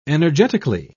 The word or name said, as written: energetically